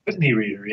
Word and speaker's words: Utne Reader: His work has appeared in Parabola, Lion's Roar, Tricycle, Utne Reader, is that how you pronounce it? – Utne Reader, yeah.